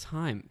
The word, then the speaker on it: Haim: It's Haim, guys. It's pronounced Haim.